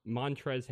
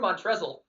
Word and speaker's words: Montrezl: Who puts a silent L on the end of their name? – It's big boy. – It's grossly weird. – And it's not him. I mean, poor Montrezl, growing up, you know, with his name.